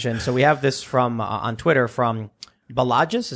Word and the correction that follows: Balaji: Oh, Balaji.